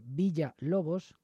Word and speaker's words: Villa-Lobos: Os recuerdo, como siempre, que se escribe Villa-Lobos con guión en medio, pero que en portugués dicen Villa-Lobos.